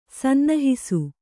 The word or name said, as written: ಸನ್ನಹಿಸು